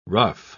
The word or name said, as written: ruff